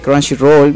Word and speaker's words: Crunchyroll: Vayan a verlo a Chunchirol. Crunchyroll. Exacto, Crunchyroll.